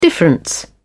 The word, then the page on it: difference: British English